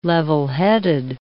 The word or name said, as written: levelheaded